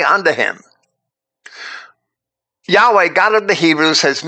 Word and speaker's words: Yahweh: So Moses is told by Yahweh to use his name, the Tetragrammaton, which we pronounce as Yahweh,